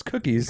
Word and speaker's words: Cuquis: Kikis Cars. – No, it's Cookies. – Cookies. – C-U-Q-U-I-S.